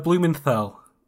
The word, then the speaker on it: Blumenthal: how do you say his name? Blumenthal. Heston Blumenthal. – Yeah, that's it.